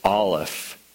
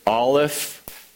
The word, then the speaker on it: Aleph: And I know you could probably care less about this nerd stuff, but the word Aleph, whenever you say it, you don't say it. It's there, but it's like, this is how my Hebrew professor, I said, how do you pronounce Aleph? He said, taking in a breath, isn't it? He said, yeah, it's exactly like that.